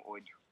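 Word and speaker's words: ODYO: It's O-D-Y-O. I pronounced it the audio.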